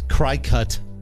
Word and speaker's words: Cricut: It's spelled weird, right? – Yeah. C R I C U K. – It's Cricut. – C R I C U K. – C U T.